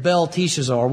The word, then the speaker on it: Belteshazzar: Notice the first syllable there in Belteshazzar. What is it? It's Bel. Bel, B-A-A-L, whether it's Baal or B-E-L, as in Bel, even up into the New Testament.